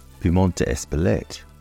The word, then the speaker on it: Piment d'Espelette: Espelette pepper, or as it is pronounced in France, Piment d'Espelette, is spelled Piment, P-I-M-E-N-T, d'Espelette, D apostrophe, E-S-P-L-E-T-T-E.